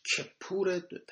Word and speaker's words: کپور: بعد جلال الدین همایی در حاشیه همین متن بیرونی تذکر داده که کبور تلفظش کپور، تلفظ درستش و با لفظ کفاره عربی یک ریشه دارد.